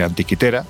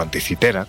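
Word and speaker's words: Antikythera: Muy cerca está la isla de Citera, porque hay que decir que la de Antiquitera, o anticitera, como, pronuncian aquí, bueno, pues salvo cabras salvajes, poco más hay.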